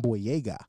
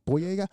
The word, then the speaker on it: Boyega: Actor from the Star Wars new trilogy, John Boyega. Happy, that's right, Boyega.